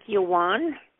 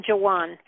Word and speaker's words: Joän: and said, Joan, which is very Scandinavian. My grandparents were all Norwegian. So it's been Joan. I spell it J-O-A-N, but I put two dots above the A.